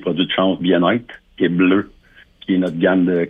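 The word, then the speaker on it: Bleuh: Comme, tu le prononces bleu, B-L-E-U-H, c'est ça? – Oui, avec un H muet, exactement bleu.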